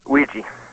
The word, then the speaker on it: WIIGII: And finally, W-I-I-G-I-I. How's it pronounced? – Ouija. – Okay. – Like the board. – Wheezy. Like Wheezy, but with a G.